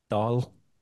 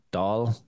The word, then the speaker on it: Dáil: So the I-F-A-D-A kind of gives it, but the A-I-F-A-D-A, yeah, gives it an A sound, like, you know, so Dáil. It's kind of like y'all, but Dáil.